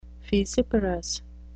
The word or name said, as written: fissiparous